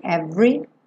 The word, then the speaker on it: every: O é do meio da palavra every não é pronunciado. Então a gente não diz every e sim every.